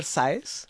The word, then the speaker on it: Sáez: Y cualquier lectura de Javier Sáez, así como se escribe, S-A-E-Z, él es español.